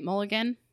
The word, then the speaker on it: Mulligan: This is Carey Mulligan's. Am I saying that right? Mulligan? – Mulligan, yeah.